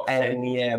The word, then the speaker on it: Ernie: di, chatbot, si chiama Ernie Bot, dovrebbe pronunciarsi così, o sì, Ernie, sì.